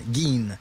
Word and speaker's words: Gein: Imagine-toi, c'est G-I-N, Gein. – Ben, parfait. – Ed Gein. Même si ça s'écrit G-E-I-N, c'est Ed Gein.